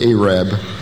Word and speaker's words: Arab: That is the way they pronounce that town, by the way, Arab.